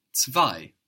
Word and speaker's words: zwei: Number two in German is zwei. – Zwei. – Zwei. Zwei is spelled Z-W-E-I. We've already seen how the Z in German is pronounced T-S.